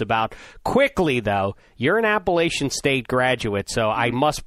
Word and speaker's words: Appalachian: First of all, it's Appalachian. Come on, let's get the name right.